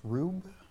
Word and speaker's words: ruwb: And I guess that's pronounced rub, R-U-W-B.